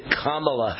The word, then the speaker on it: Kamala: The word comma, like a comma in a sentence, comma, period. Kamala Harris is the way she wants it pronounced or thinks it should be pronounced.